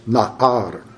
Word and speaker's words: na'ar: That word is na'ar. N-A apostrophe A-R. Na'ar. You got to roll your R's, and if you're Scottish, you can pull it off, laddies and lassies.